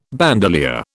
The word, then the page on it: bandolier: British English